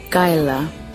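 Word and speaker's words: Kialla: Another town in Victoria, just south of Shepparton, called Kayala. We use the first syllable, Kayala, and we create the K more like a G and K combination.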